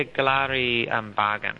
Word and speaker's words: Galari Bargan: new exhibit, Galari Bargan, am I saying that correctly? – Yeah, Galari Bargan, yeah.